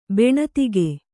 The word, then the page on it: ಬೆಣತಿಗೆ: beṇatige